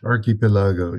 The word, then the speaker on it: Archipelago: How do I say it? – Archipelago, yeah. – Archipelago. Well, you're an Italian guy. I'll go with that.